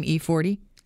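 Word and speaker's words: E-40: Is that how you say his name? E40? Yep, exactly.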